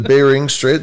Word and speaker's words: Bering Strait: Because if you were standing on the other side, because you're talking, I'm going to butcher it, Beringer Strait. – The Bering Strait, the Beringer Strait, the Bering Strait.